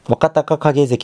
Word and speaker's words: 若隆景: あの、この優勝した力士、若隆景関、若隆景関なんですけど、いやアナウンサー泣かせですよね。若隆景って読みづらいっすもん。こうやって、若隆なのに、こう、影なのが読みづらいんですよね。